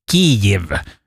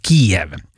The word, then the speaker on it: Kyjiv: Bland annat huvudstaden som nu ska stavas K-Y-J-I-V, Kiev, uttalas det. Istället då för det här välbekanta Kiev.